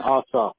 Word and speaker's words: Ossoff: And how about John Ossoff? I think I believe that's how you pronounce that. – Yeah, Ossoff. Ossoff is 7.